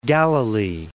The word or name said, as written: Galilee